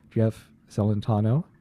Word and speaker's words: Jeff Celentano: With us we have director Jeff Celentano and Ricky Hill, who's the subject that the movie is based on.